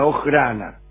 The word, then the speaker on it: Ojrana: Siendo su primo, comenzó también, se metió en la policía secreta del zar, en la Oirana. Oirana, se escribe con J, Ojrana.